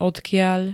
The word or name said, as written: odkiaľ